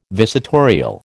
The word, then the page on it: visitorial: American English